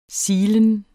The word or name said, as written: silen